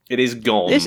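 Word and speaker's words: Goon: Is it Gords or Gons? – Gons? Gons? I can't remember. It's G-O-O-N, but I've forgotten can't remember how to pronounce it. – It is Gons.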